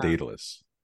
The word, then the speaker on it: Daedalus: There's a few pronunciations. I call it Daedalus.